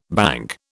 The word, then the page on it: banc: British English